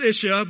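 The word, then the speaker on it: ish-ah (אישה): And in Hebrew, the word for man is ish, and the word for woman is ish-ah. Probably pronounced ish-ah, but I just give a little flair there.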